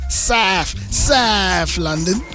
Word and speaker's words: South London: You said it with a TH. South London. There you go.